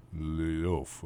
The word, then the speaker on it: l'œuf: which in French is l'œuf. Okay, fine. That's not even close. When tennis became popular in this country, America, Americans copied the French and also called the zero score l'œuf, but pronounced it love. – Okay. – So maybe that is almost how you pronounce it. L-O-E-U-F.